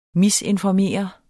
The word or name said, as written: misinformere